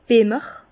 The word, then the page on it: Bemmer: Bas-Rhin; Herrlisheim